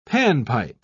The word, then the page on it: panpipe: British English